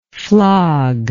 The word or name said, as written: flogger